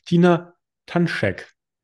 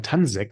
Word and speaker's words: Tanšek: Tina Tanschek. Ich hoffe, das habe ich richtig ja. ausgesprochen, das ist äh, vielmehr schwer, ich habe mal Tansek gesagt.